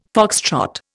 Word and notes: foxtrot: Indian

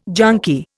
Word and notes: junkie: Indian

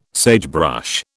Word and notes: sagebrush: British English

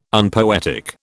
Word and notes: unpoetic: British English

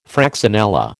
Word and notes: fraxinella: American English

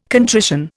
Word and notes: contrition: Indian

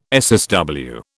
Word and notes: SSW: British English

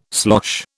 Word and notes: slosh: British English

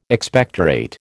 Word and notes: Expectorate: American English

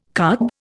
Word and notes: carb: Indian

